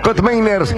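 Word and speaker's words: Koopmeiners: Está bien la pronunciación de Kotmeiners. Ahí está, Kotmeiners.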